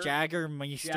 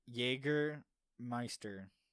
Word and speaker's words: Jägermeister: Jaggermeister. – Jaggermeister. Mick Jaggermeister. – Jaggermeister. – I mean, if you really read the label... – Sound it out. – Jaegermeister.